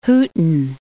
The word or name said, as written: Hooton